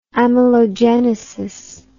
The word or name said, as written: amelogenesis